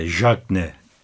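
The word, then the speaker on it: Diagne: Mesela İşte ligin gol kralı Jacne diye okunuyor. Bazıları daha farklı ifade ediyor ama Kasımpaşa'nın gol krallığında 20 golle ilk sırada bulunan santrforunu Galatasaray takımı transfer etti.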